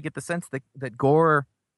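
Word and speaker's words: Gorr: Gore just, I just realized now that Gore rhymes with Thor.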